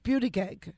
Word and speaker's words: Buttigieg: But it's pronounced Buttigieg, and this poor man thinks his name is Buttigieg.